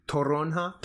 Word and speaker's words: toronja: Crush grapefruit or toronja. – Or pamplemousse. – Am I saying it right? – Yeah. – Toronja.